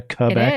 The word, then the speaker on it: Quebec: There was a shocking clip this week out of Quebec or Quebec. Is that how they say it in French Canada? Quebec. – It is.